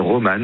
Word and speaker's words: Roman: Je m'appelle Roman, mais ça s'écrit roman, comme un livre, R-O-M-A-N, tiré du bas, sens, S-A-E-N-S, comme le compositeur Camille Saint-Saëns, roman, tiré du bas, sens, S-A-E-N-S, et sur Instagram et TikTok.